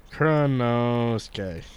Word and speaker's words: Kuranosuke: I literally have a note with how you told me to pronounce it. – Nice. – Because I can't remember. Kuranosuke. Okay. I wrote it phonetically in English, which is wrong.